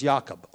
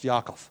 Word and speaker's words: Yaakov: The name Jacob is, the Hebrew name is Yaakov, or Yaakov, depending on how you want to pronounce that final consonant, but it's generally pronounced Yaakov, or Yaakov.